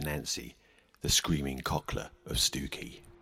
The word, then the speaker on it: Stiffkey: Continuing on the theme of rather loud female spirits, let's head on over to Stewkey. Yes, it's spelt Stiffkey. But locally, it's the rather lovely seaside village of Stewkey.